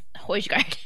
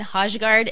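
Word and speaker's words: Højgaard: I was saying Hojgaard, and no, I was told it is Hoygaard.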